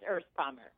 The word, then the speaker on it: Erspamer: Okay, it's Erspamer.